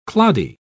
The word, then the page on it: cloddy: British English